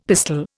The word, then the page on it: pistol: Indian